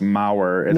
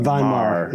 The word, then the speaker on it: Von Maur: And for those wondering, it is not pronounced Maurer. – No, Von Maur.